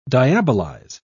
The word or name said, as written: diabolize